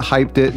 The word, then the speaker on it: Hypeddit: So if you want to check out Hyped It, and by the way, Hyped It is spelled like with two D's. So it's like Hyped, H-Y-P-E-D, and then D-I-T. It's all one word, Hyped It, H-Y-P-E-D-D-I-T.